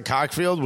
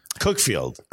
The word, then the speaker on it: Cuckfield: Don't they pronounce it Cockfield, which... Cookfield.